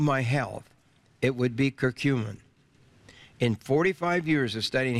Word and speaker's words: curcumin: Now, we do have the offer on the curcumin.